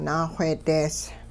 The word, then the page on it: naaxweedees: Hupa